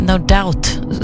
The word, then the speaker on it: No Doubt: No doubt. No doubt. Doubt. Det är sånt där ord som slösar på bokstäver som man inte ens behöver säga. No doubt ska man uttala det.